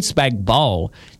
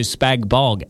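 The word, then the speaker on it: spag bol: Spag bowl, the G is silent.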